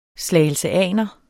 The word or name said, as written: slagelseaner